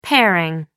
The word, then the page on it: pairing: American English